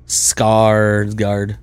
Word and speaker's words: Skarsgård: How do you pronounce the A's with the two dots over it? – I mean, Skarsgård says it's an Ah. Skarsgård.